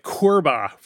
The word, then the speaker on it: CORBA: And so then there were all these formats like CORBA, which it's like Cobra, but it's spelled wrong, and it stands for the Common Object Request Broker Architecture, I think.